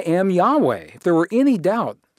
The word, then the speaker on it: Yahweh: this name, YHVH, pronounced Yahweh,